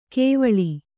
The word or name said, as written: ಕಾವಳ